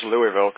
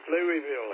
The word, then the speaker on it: Louisville: And I think Louisville, Kentucky, spelled Louisville, but pronounced Louisville, Kentucky, is probably that's where the Kentucky Derby is held, at Churchill Downs there. – That's it, Louisville.